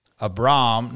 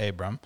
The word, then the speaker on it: Abram: But here's the thing, and I, and I realized so, first of all, I found out at Memphis that it's um, Abram, not Abram. – Okay. Abram and rhymes with Philipp Lahm.